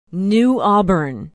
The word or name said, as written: new auburn